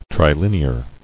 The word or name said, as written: Trilinear